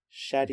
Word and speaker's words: Shadyac: It's like Shady, like the real Slim Shady with an AC on the end. Shadyac.